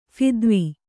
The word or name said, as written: ಫಿದ್ವಿ